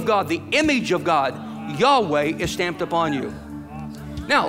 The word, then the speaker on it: Yahweh: It's only as man and woman come together, stamped upon them is Yahweh, yod, hey, vav, hey, Yahweh. We mispronounce it Jehovah.